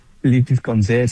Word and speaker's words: Lipis con C: Lipis con C, sí, sí la pronuncia bien.